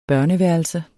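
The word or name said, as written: børneværelse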